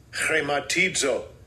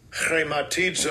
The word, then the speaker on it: chrematizo: This is how you pronounce it. – Strong's G5537. Chrematizo. Chrematizo.